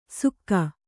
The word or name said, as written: ಸುಕ್ಕ